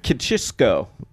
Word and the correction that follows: Klitschko: Yeah, her boyfriend, Ukrainian heavyweight boxer, a big, big name, Vladimir Klitschko. – Oh, I've heard of him. He's when you say it right.